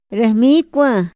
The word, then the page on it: ra hmikua: Otomí